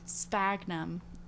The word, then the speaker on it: sphagnum: Yeah, and then over thousands of years, the sphagnum. It's SF, like that type of sound. Sphagnum.